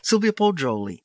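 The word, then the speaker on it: Sylvia Poggioli: Sylvia Pacioli, NPR News, Rome.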